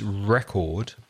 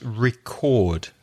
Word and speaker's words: record: So the noun is record, but the verb is record. Notice the stress changes on the verb. So a record, a vinyl record, stress the first syllable, but the verb to record music, stress the second syllable.